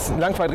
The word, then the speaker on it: Langquaid: Das ist schon in der Nähe Langqueit richtig ausgesprochen. Richtig ausgesprochen.